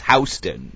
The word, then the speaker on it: Houston: It's spelled Houston, but it's Houston, you know, in New York.